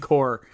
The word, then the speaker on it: Corps: you said corpse. – The CCC. – I know, you meant the Conservation Corps. – Corps, sorry. It's spelled like corpse.